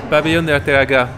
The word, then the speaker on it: Pabellón de Arteaga: Pabellón de Arteaga.